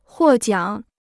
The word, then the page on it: 获奖: huò jiǎng